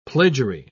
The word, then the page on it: plagiary: British English